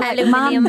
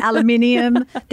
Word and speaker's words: aluminium: In aluminium. There's actually an extra I in it. Did you know that? It's spelt differently. – Yeah, that's why it's pronounced differently.